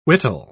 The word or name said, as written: whittle